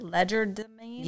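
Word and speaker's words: legerdemain: I wonder what how did you say that? Ledger domain? – Yeah. It's a sleight of hand type of magic.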